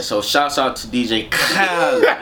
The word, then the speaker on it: Khaled: Khaled.